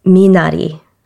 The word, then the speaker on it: minari: It's minari. – The R sound is almost like a D sound to me.